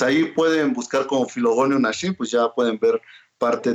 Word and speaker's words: Naxin: Nashin se deletrea N-A-X-I-N. – Perfecto. Y se pronuncia Nashin. Nashin.